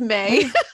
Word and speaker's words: Mae: And it's May. – Stop. Wait, what is the spelling? – My name is spelled M-A-E. And, like, for a while, I was writing M-A-Y because,